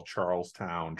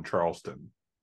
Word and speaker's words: Charlestown: I hate when people in Rhode Island call Charlestown Charleston.